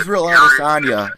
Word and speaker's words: Israel Adesanya: Israel Adesanya.